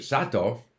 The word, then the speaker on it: Sato: And I've said, when I was in Japan and I'd have visitors from North America who would be introduced to Mr. Sato, some people would say Mr. Sato because it's written S-A-T-O. So they see the name card, S-A-T-O, that should be Sato. Even though they hear him pronounce his own name as Sato. So they don't notice the pronunciation. Other people will immediately hear Sato and go Sato.